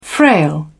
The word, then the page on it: frail: British English